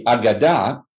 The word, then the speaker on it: Aagada: Let's talk about is it the Agada? Did I pronounce that? – Yep, correctly? Sure. The Agada, A-A-G-A-D-A.